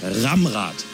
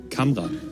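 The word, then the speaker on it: Kamrad: Genannt wurde er nämlich in der Vergangenheit auch zum Beispiel schon Ramrad. Warum auch immer, Ramrad ist nicht der Name. Hier, Camrad, ausgesprochen Camrad.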